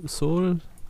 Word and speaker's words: Seoul: Mh, mm, Seoul? Seoul? Seoul? die südkoreanische Hauptstadt, wo wir das schon mal falsch ausgesprochen haben, was ich jetzt ja, wahrscheinlich ich glaub, gerade wieder getan habe. was ich gerade wieder also, ja. wie die Seele.